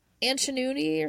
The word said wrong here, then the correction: Antonucci: or something. Antonucci. – Antonucci. – Antonucci. Okay. So Tony Antonucci was working for Gacy when one day on a job site he stepped on a nail.